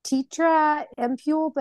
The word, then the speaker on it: Tirta Empul: I say Tirta Empul, but how do you pronounce that? – Tirta Empul.